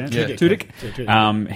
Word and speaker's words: Tudyk: It's got Alan Tudyk. I think I'm pronouncing that right. – Tudyk.